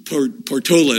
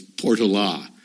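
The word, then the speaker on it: Portolá: He is the person who, among other things, taught me the pronunciation of Portola. Portola is what I have said all of my life. Portola Valley, Portola Elementary School, Portola whatever. But he tells me that it was Portola.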